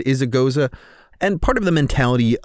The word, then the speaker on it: Gose: Our beer today is Wheels Goes Around by Left Hand Brewing, and yes, that's pronounced Goza.